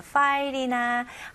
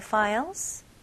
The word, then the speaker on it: file: Did you send me the files? Did you send me the files? Okay? 여기서 file, F 발음, F, F, file, 파일 아닙니다.